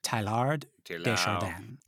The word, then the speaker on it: Teilhard de Chardin: Pierre, T-E-I-L-H-A-R-D, De, D-E, and then C-H-A-R-D-I-N. Which I think is Chardin. – It looks like it to me, yeah. – Yeah, so Pierre Teilhard de Chardin.